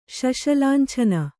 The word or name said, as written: ಶಶಲಾಂಛನ